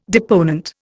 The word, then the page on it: deponent: Indian